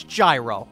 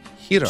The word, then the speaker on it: gyro: It just, it's pronounced the way it's spelled. It's gyro. Enough with the fancy schmancy. We're not in Greece. – It's properly pronounced hero.